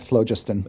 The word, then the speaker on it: phlogiston: You could call it phlogiston. – Okay, phlogiston.